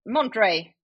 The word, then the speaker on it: Monterrey: Man ska väl köra spanskt uttal. – Ja, och där är du ju stark. – Ja, jag vet inte. Monterrey! – Ja, det kommer inte bli så. Jag kommer inte anamma det. – Jo, det är något form av, det är ju betoningsspel. Alltså det är ju Monterrey.